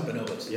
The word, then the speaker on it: Bonobos: Is that how you say it? Bonobos? – I think so. – Bonobos. – Yes.